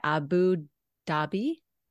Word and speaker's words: Abu Dhabi: Thus far, she's coached people from over 75 nationalities, including, oh my goodness, the Royal Princess, how do you say it, Abu Dhabi? – You got it, yeah.